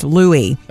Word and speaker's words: Louis: By the way, the baby's name is pronounced Louis, the traditional French pronunciation where you drop the S.